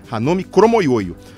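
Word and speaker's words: Ranomi Kromowidjojo: O nome dela se escreve Kramovidjojo, tem W, J, D no mas eu aprendi numa entrevista dela que se fala Hanomi Kromoyoyo três medalhas de ouro, uma medalha de prata em Jogos Olímpicos, tricampeã mundial, mais um monte de pódios em campeonatos mundiais também.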